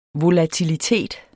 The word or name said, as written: volatilitet